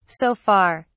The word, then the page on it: so far: British English